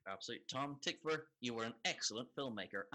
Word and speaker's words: Tykwer: Also, so it's Tom Tykwer is how you pronounce it. And we're going to get letters, that's why I'm telling you, because I had to look it up as well. And apparently the W is pronounced like a V. So it's Tykwer.